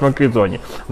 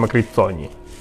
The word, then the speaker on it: Макрицоні: Ну, правильно взагалі треба казати Макрицоні, тому що це італійське є прізвисько, чи прізвище, прізвище. Італійське, і воно читається як Макрицоні. взагалі. Але так як у нас люди дві з англійських читають як З, то всі кажуть Макрізоні. Насправді, насправді правильно казати Макріцоні.